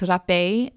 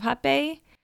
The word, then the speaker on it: rapé: However, in Lorna Liana's ebook on hapé, she explains that, so R-A-P-E with a little accent over the E is the Portuguese word for snuff, and R in Portuguese sounds like an H.